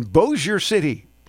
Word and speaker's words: Bossier City: Lex in Bossier City. Is that how you say it? – That is. – You've always corrected me on that one.